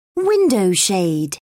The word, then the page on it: window shade: British English